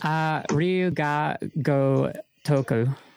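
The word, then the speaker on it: Ryu Ga Gotoku: Ryuga Go Toku.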